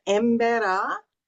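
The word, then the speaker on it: Emberá: tribe that I married into is called the Embera, It's EMBERA, accent on the A.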